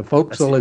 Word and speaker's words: forecastle: And then when you write it, even when you write it forecastle, it's pronounced foc'sle. – So, but it is still the poop deck, yes? – No, that's the opposite. The foc'sle is the forward and the poop deck is the stern.